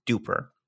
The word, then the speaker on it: DUPR: It's spelled D-U-P-R, Duper. So not quite like Super Duper, but it is Super Duper.